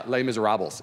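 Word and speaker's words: Les Misérables: Okay, Les Miserables is how it sounds phonetically.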